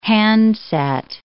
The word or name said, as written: handset